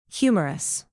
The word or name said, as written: humerus